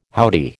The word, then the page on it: howdy: American English